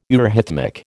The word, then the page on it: eurhythmic: American English